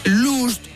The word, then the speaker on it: lost: Porque vamos a ver, last, L-A-S-T, es... – último. – Lost, L-O-S-T, es... – perdido. – Y lost... que no se pronuncia luz, sino que se dice también lost. – Last, L-U-S-T, significa...